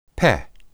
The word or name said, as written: peh